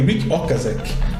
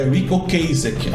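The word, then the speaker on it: Ric Ocasek: Porque eu, eu pronunciei com acento errado. Eu falei Rick Okazek né? Que é o cara que tinha morrido, do The Cars, e é Rico Kasek, a pronúncia do nome correta.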